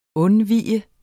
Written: undvige

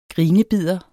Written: grinebider

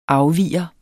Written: afviger

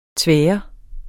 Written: tvær

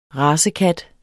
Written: racekat

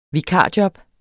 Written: vikarjob

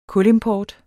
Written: kulimport

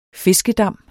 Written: fiskedam

